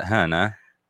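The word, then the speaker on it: Hönö: That's how they pronounce it, Hana. – That's so cool. – It's spelled H-O-N-O.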